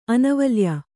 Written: ಅನವಲ್ಯ

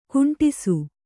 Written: ಕುಂಟಿಸು

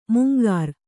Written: ಮಂಗರೆ

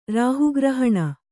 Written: ರಾಹುಗ್ರಹಣ

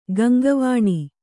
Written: ಗಂಗಾವನ

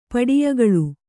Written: ಪಡಿಯಗಳು

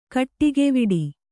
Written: ಕಾಡಿಗೆವಿಡಿ

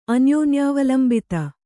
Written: ಅನ್ಯೋನ್ಯಾವಲಂಬಿತ

